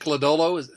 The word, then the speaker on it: Lodolo: And that's Nick Lodolo.